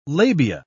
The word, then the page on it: labia: British English